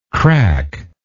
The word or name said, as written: crackless